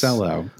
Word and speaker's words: Cancelo: It was like a weird, like, it was like Kyle Walker played for a half and is it Cancelo? Cancelo, however you say his name? – Cancelo, I think it Cancelo. – Is. – Cancelo, yeah. – Not that we've ever been right about pronunciations on this podcast, but I'm going Cancelo.